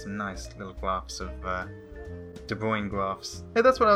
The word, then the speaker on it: De Bruijn: They're called De Bruijn sequences. – De Bruijn? – Brown is spelled... Yeah. – B-R-U-I-J-N. – Yes, that's it. – That's what they're called, eh? De Bruijn sequences.